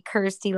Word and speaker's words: Kirsty: So because my name rhymes with thirsty, Kirsty like thirsty, I also had a band in the Midwest when I lived up there.